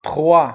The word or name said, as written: trois